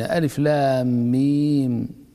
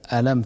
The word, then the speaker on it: ألف لام ميم: من حيث الكتابة واحد، فأنت لو كتبت ألم ترى إنما تكتب كما تكتب ألف لام ميم، لكن نطقك بألف لام ميم هو ذكرك أو نطقك باسم كل حرف،